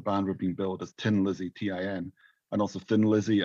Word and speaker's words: Thin Lizzy: And there's a robot in that called Tin Lizzy. You know, the Irish pronunciation of thin is tin. You say Tin Lizzy.